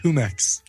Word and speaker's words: Jumex: Jumex. – Right. I think somebody corrected us. That's pronounced Humex.